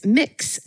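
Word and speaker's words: Mx.: And the non-binary Ms. is mix. It's spelled M-X, period. – Right. Yeah. – Most often pronounced mix.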